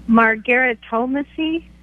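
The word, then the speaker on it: Margaritomancy: Margaritomacy? – Good pronunciation. – That's pretty good.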